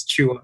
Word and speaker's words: Chua: It's a silent T. It's Chua.